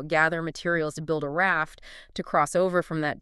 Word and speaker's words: RAFT: And we pronounce it simply as the spoken word raft, just, like the boat.